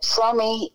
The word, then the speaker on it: Fromme: Fromme is the way Fromme. – It's pronounced. – Right, Lynette Fromme. – It's German. – Right. – There are, Fromme, I believe, is a Yiddish or German, I mean, a Jewish pronunciation without the E.